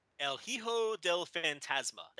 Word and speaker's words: El Hijo del Fantasma: El Hijo del Fantasma. That was not even there it is, that El was Hijo. That was not even hard, Rich.